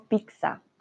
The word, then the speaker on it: pizza: Algo bien particular, nosotros decimos pizza, como con X, pizza. Los italianos siempre nos miran con cara de, hmm, ¿qué están diciendo? Pero es común en Latinoamérica decir pizza y no pizza. ¿Vale? Pronunciamos comúnmente con X.